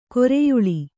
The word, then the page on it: ಕರಿಯಾಲೆ: kriyāle